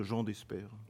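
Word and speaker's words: Jehan Despert: Jeanne Despert, je ne sais pas si je l'ai bien prononcé. – En fait, ça, peut se dire Jean Despert. – Jean Despert, ce sera mieux comme ça, qui est avec Michael Lonsdal.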